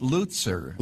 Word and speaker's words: Lutzer: How do you say it, Pastor? – I call it Lutzer. – Lutzer. Well, what do you know? – That's the correct pronunciation.